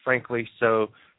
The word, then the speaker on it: Frank Liso: Welcome to another very exciting and interesting episode of The Sideshow with Frank Liso and Tenoro. Yeah, for those of you who have seen my name and how it's spelled, it's actually pronounced Frank Liso, not Frankly Sucks.